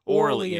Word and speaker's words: Orly: It's like the name of the Paris airport or something, or French. – Or Orly. Orly is her name.